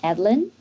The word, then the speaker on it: Adeline: My name is Adeline. It's pronounced as Adeline, French name.